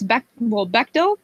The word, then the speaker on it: Bechdel: Am I saying that right? – I think it's Bechdel. Is that what you said? – Bechdel, yeah. Bechdel.